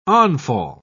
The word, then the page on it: onfall: British English